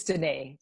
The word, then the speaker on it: Danae: It's Danae.